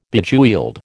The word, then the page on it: Bejeweled: American English